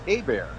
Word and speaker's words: Hebert: But anyway, yeah, my last name's actually pronounced Hebert. It's a Louisiana French, and so I know it looks like Hebert, and sometimes people get misspell it as Herbert. But the H is silent and the E is pronounced like an A, so it's like a bear is attacking me.